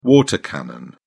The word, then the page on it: water cannon: British English